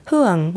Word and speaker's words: Hung: So for people who are Vietnamese, you'll know that my name is actually pronounced Huang, which is the Vietnamese correct pronunciation. However, Hung is the way that I've pronounced it for a long time, and I honestly don't remember how I got to that pronunciation.